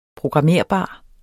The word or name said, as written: programmerbar